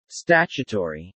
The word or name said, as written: statutory